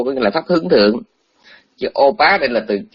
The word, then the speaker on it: thượng: Thì trong tiếng Hán đó cũng một chữ đó thôi, nhưng mà hết nghĩa trạng từ họ đọc là thượng, có nghĩa là ở trên.